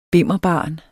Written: bimmerbarn

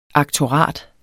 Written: aktorat

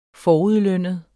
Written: forudlønnet

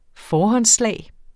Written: forhåndsslag

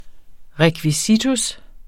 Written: rekvisitus

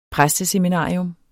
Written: præsteseminarium

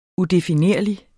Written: udefinerlig

